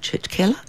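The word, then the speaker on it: churchkhela: Nups and grates, called, and I'm probably going to get this pronunciation wrong, it's church is the first part of it, which is easy, Church Keller, K-H-E-L-A, it's called Church Keller.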